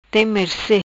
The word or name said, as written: Merce tem